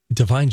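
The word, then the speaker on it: Da'Vine: It's spelled D-A apostrophe V-I-N-E. – Love it. – Sounds like divine.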